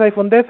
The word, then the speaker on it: iPhone X: Vale, pronuncias iPhone 10, ainda que se escribe iPhone X eh,